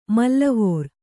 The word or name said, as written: ಮೇಲೆವರ್